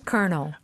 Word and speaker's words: colonel: So, it's kernel and it's spelled C-O-L-O-N-E-L. C-O-L-O-N-E-L. No R.